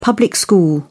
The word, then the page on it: public school: British English